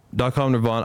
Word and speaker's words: Nirvan: And then DotCom Nirvan was like, people pronounce my first name all types of ways, like Nirvan and Nirvan and shit. So DotCom Nirvan made it hard to not pronounce right.